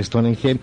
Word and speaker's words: Stonehenge: El enigma de Stonehenge, tiene muchas pronunciaciones. Nosotros vamos a decir Stonehenge,